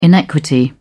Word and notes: inequity: British English

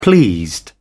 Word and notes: pleased: British English

